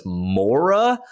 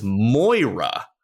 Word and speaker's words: Moira: Like the one thing I don't like about Robin Williams in this movie, which still bugs me to this day, is that he pronounces his wife Moira's name as Mora the whole movie. And it drives me nuts. Like, her name is Moira.